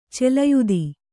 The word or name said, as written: ಚಲಯುತ